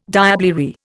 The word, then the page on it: diablerie: Indian